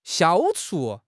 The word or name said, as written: 消除